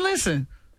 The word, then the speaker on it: listen: Listen is another one where the English words has a silent letter. – Silent and listen? – T. Listen. – Listen. – You don't hit no T in listen. Listen.